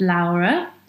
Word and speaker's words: Laura: My name is Laura. Actually, in Italian, it's pronounced Laura, but I like both, so you can choose.